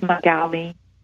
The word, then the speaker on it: Magali: Her name is Magali. – Magali, spell for me. – Yeah. Spell it is M-A-G-A-L-I. – Magali, just like it sounds.